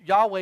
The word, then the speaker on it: Yahweh: An evil spirit from Yahweh, which Yahweh is the Hebrew pronunciation of God's name, Yahweh, in other words, permitted by Yahweh, terrorized the man.